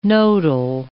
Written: nodal

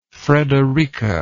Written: frederika

